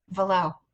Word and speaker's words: Valot: My last name's French and has a silent T. – What is it? – Valot. – Valot. Oh, I like that. – So it's V-A-L-O-T, but you never would have guessed that, would you? – No.